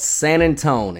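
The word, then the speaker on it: San Antonio: On February 23rd, 1836, in a small town called San Antone, and yes, that is how you pronounce it if you're from here, the forces of the Mexican army, led by Santa Anna himself, descended upon a small fort called the Alamo.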